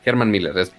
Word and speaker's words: Herman Miller: de una colaboración de Logitech con Harman Miller que mm. Herman Miller, se pronuncia así Herman Miller.